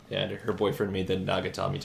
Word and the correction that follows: Nakatomi: Tower. How do you pronounce that? Yeah, Nakatomi.